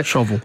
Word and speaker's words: shovel: You know, even a word like a shovel used to rhyme with grovel. So shovel.